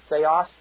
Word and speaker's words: theios: First of all, there is an adjective which means godlike, and it's, though it's pronounced the same way, T-H-E-I-O-S, theos, theophion.